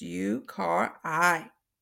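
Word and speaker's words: uakari: The yukari is pronounced U-car-I.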